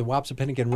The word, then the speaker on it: Wapsipinicon: I was in Iowa, and I had this big, long word, and I just went, the Wapsipinicon River. And I looked up at my co-host and it looked at us, okay, well, how do you pronounce it?